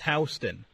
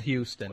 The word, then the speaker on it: Houston: Yeah, and it's actually pronounced Houston, not Houston.